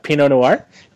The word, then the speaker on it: Pinot Noir: I know it's French pronunciation. – It's after a wine. – Yeah, Pinot Noir. It's like Noir would be night.